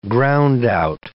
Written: groundout

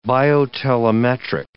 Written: biotelemetric

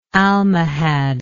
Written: Almohad

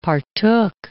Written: partook

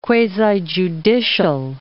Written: quasi-judicial

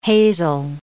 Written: hazel

